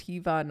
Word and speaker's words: HIWA: It's HIWA, which is pronounced Hiva, not Hiwa.